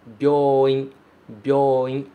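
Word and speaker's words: Byoin, byoin (病院): Muito cuidado com essa pronúncia. Byo, byo. Tem que ser rapidinho mesmo. Byoin, byoin. Se você demorar muito nesse Y e falar byoin, você acaba falando outra palavra em japonês.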